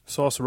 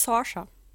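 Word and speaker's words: Saoirse: Source Ronan? – Nein, sie heißt Sorsha.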